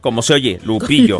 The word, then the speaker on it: Lupillo: Con doble L. Ajá. – Lupillo. – No con Y. Sí, porque luego hay quienes lo quieren pronunciar como Yupillo.